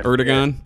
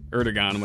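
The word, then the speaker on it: Erdoğan: Erdogan. – Erdogan.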